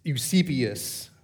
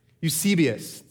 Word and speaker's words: Eusebius: So this was recorded by a third century historian, a Christian historian named Eusebius. That's not how you pronounce it. But that we'll just keep going. Eusebius.